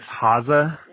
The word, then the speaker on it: Haase: I mean, there's like 10 ways to pronounce it. I think really it's Haase, which means bunny Haase? – Rabbit in German.